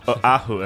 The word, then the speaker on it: Oahu: Pronounce every syllable. – Oahu. That's how they do it, man.